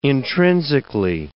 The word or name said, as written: intrinsically